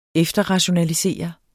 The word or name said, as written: efterrationalisere